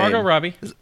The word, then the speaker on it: Margot Robbie: I hope I'm saying that name right. She's like super famous. I don't know how to say her name. – Margot Robbie. You got it.